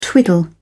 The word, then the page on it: twiddle: British English